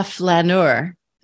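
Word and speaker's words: flâneur: Be a flaneur. Am I saying that right? I hope so. – Perfect. – Absolutely perfect.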